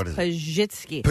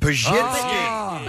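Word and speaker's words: Pazsitzky: Pazitsky. Is that right? Am I wrong? – It's so close. It's Pazitsky. – Pazitsky.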